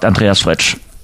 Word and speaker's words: Andreas Fritsch: Habe ich das richtig gesagt, Andreas Fretsch? Absolut korrekt. Grüße in die Runde.